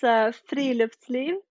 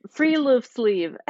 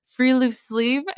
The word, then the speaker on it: friluftsliv: Yeah, it's Friluftsliv. – Okay, I definitely can't say it like that. – Okay, so like Friluftsliv. That's the Americanized pronunciation.